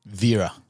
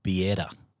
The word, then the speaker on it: Vierya: Vierya. – Because I always say Vierya. – Yeah. – And people get that wrong? – Yeah, it's the correct pronunciation is actually Vierya.